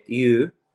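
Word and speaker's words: yu (鱼): si dice yu, eh, che è una sorta di yu inglese.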